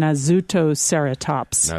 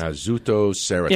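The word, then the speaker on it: Nasoceratops: Nossoceratops. I don't think I pronounced that correctly. – I think we said it before when we talked to Scott Sampson, Nossoceratops. – Nossoceratops.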